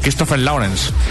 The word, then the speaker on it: Christopher Lawrence: Y los artistas, que esto es importante, son John 00 Fleming y Christopher Lawrence.